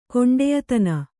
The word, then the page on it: ಕೊಂಡೆಯತನ: koṇḍeyatana